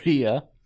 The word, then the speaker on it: Rhea: I call her Ray. I don't know why. – Okay. – Rhea Ripley versus Toni Storm for the NXT UK Women's Championship. – You have to pronounce the H. – Okay. Rhea, like that? – Yeah.